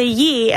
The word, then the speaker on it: Yee: And I say Yee, and they're like, what did you say? How do you spell that? And I'm like, it's Y-E-E. And they're like, E-Y-E-E?